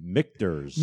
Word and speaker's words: Michter's: If you can pronounce it. – It's Michter's.